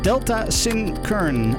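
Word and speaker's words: Delta Sin Kern (ΔSYN.0X): Het wordt geschreven als driehoekje SYN puntje 0X. Maar het wordt uitgesproken als Delta Sin Kern.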